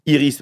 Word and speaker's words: Iris: Iris, bah, comme le prénom, et Dement, ça s'écrit D-E-M-E-N-T.